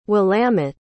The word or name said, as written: willamette